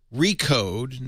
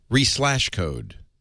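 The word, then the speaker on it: Re/code: Now, Liz, do I say Recode now? That's the official name. – Yeah, you don't have to pronounce the slash. It's silent. – Re-slash code. – No, you don't have to say that.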